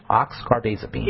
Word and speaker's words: oxcarbazepine: They just went right into the oxcarbazepine. Oxcarbazepine. – Yeah, is how you say it.